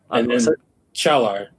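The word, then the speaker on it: Çağlar: Chalar is his first name. That's how you pronounce it. And then Soyuncu is his last name.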